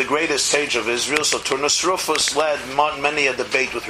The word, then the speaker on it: Turnus Rufus: The representative of Rome in Judea was a man named Turnus Rufus. Okay? Four syllables. Turnus Rufus.